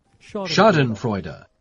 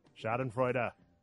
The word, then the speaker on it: Schadenfreude: Schadenfreude. – There it is. Schadenfreude. Put one on the board for me pronouncing a word correctly.